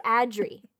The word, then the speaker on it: Adri: It's like pronounced out for you with Adri. It's just Ad and Re. It's literally right there.